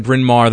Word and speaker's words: Bryn Mawr: And how do you say Bryn Mawr? Is that where you're from? Bryn Mawr.